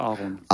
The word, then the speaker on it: Aaron: Spricht man wahrscheinlich Englisch aus, oder Aaron? – Ich sage Aaron.